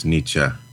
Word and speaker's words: Nietzsche: Also, can all Americans please stop pronouncing Germanic names as if the E on the end sounds like an E? Haneke is actually Haneke. Nietzsche is Nietzsche. I hear you say Haneke and I reach for my hanky.